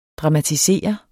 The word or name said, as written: dramatisere